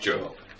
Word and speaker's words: Job: It's not Job, it looks like it's Job, but it's pronounced Job.